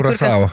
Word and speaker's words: Curaçao: Curaçao.